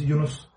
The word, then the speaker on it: Yunus: The real pronunciation is Yunus.